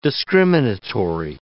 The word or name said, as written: discriminatory